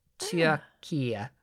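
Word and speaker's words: Türkiye: Like, I think it's still pronounced the same, but they spell it differently now. Like, it's T-U-R-K-I-Y-E. Turkia.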